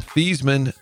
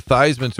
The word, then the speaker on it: Theismann: Yeah, I mean, Joe Theismann changed his name from Theismann to Theismann, so it would rhyme with Heisman.